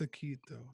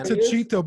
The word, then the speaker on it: Taquito: Is that Tachito? Taquito? – I think it's Taquito. – Oh, Steven's got his video on. – It's not. It's not Tachito because you don't pronounce the H like that.